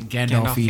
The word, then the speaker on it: Gandolfini: Gandolfini.